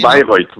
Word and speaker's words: Bayreuth: no sé cómo se pronuncia, Beirut en alemán o Bayreuth o algo así. – Bayreuth, sí.